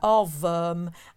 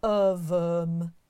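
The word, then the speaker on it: Ohrwurm: And the word is, I'm not sure how to pronounce it, so apologies in advance, David. Ovum. – How are you spelling that, please? – It's O-H-R-W-U-R-M. – Oh, yeah. – Ovum. And it's the German word for earworm.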